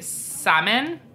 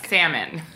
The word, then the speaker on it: Samhain: I'm going to say Samhain. – Yeah, sure. – Say it like it's not spelled.